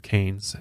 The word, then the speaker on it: Cairns: I guess it's not huge by any means, but a city called Cairns. I believe I'm saying that right. It's spelled C-A-I-R-N-S, but in Australia they pronounce it as Cairns, which is kind of interesting.